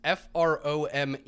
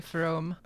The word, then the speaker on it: Frome: So we got this first one, which uh, for our dear listeners is F R O M E, which I would assume is pronounced like from, like Jake Fromm, the quarterback.